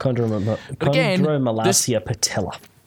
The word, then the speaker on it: Chondromalacia patella: It goes from complete chaos to this song, and then the next song, which is, bear with me while I try and pronounce this, Chondromalacia patella, I think, yes, is how you say it. I am probably butchering that so badly, and I apologize for that. – Chondromalacia patella. – That's the one.